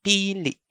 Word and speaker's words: pili: The next pronunciation is pili, pili. The first vowel is prolonged, while the second vowel has a stop. That's pili, pili. Notice the oomph at the end of the li sound.